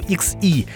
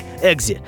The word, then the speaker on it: XE: На самом деле, правильно было бы называть его XE, а сами англичане и вовсе произносят название машины как «Exe», «Jaguar Exe».